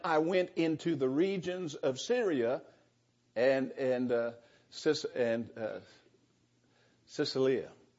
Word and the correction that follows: Cilicia: How do you say that? I get things in my mind, then I can't say it. Say it again. Cilicia. I knew that.